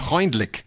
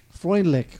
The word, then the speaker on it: Freundlich: Freundlich. – Freundlich, who is married to Julianne Moore.